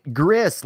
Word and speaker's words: Gris: You said it right, Gris, like Chris. It is. It apparently means gray.